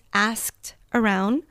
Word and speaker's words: asked around: Past tense, asked around. The ED is going to make a T sound at the end. Asked around.